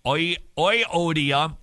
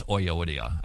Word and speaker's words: Euodia: I think it's Euodia. I've heard Euodia. But I guess it depends on whether you studied German Greek or Latin Greek. But there's two different types of pronunciation. There's the German pronunciation of Greek words. That's the one that I got. So it's EU is oi.